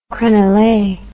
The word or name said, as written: crenelle